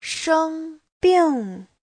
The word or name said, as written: sheng1bing4